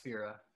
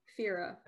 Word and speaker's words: Fera: You say her name is Pira Muntar? – Fira. – Fira. – F-E-R-A. Okay.